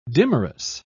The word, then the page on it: dimerous: British English